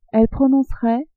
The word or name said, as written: elle prononcerait